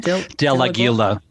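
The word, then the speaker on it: Del Aguila: How do you say Kevin's last name? – Delagila.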